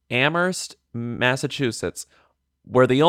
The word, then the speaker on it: Amherst: It said it said Amherst, Massachusetts, where the only thing silent is the H. – Amherst. Amherst. – Amherst because the idea is you don't pronounce the H.